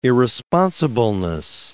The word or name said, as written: irresponsibleness